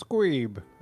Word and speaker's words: Squeeb: You too, Gweeb, was it? – Didn't quite hear the way you pronounced it, but I'll just say it so you know for next time. It's Squeeb.